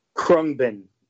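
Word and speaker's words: Khruangbin: Krungbin. – Krungbin.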